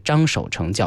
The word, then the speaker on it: 张守成: 张守成教授把这个字呢自己愿意读作成啊，所以呢我们在节目当中所称呼到的是张守成教授。